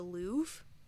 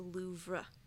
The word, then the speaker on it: Louvre: Okay, and so I always thought you pronounced this the Louvre, but it's actually the Louvre. Like, there's an R in it, and you think it would be silent, but it's actually not.